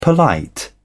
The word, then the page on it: polite: British English